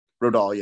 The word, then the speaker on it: Rodal: Rodal, yeah.